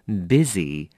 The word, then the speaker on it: busy: 这个字呢，在这边呢，我要提醒你的是 busy, 它念成 busy, 但是它拼成 b-u-s-y, 所以很多人常常会搞不清，尤其是刚刚开始学的这些 beginners, 常会把这个字拼错了哦。那你要小心了， busy 是 b-u-s-y, but we pronounce it as busy, 好吧，要小心它的发音了哦。